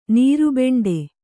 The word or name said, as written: ನೀರುಬೆಂಡೆ